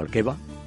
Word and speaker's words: Alqueva: Y otro es un lago, el lago Alquera, o Alqueva, según lo quieras pronunciar en castellano, lo quieras pronunciar en portugués,